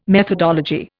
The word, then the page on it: methodology: Indian